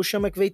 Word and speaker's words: Sean McVay: O Zach Taylor tem 38 anos, head coach do Cincinnati Bengals, o Sean McVay 36. Falei certo? Sim.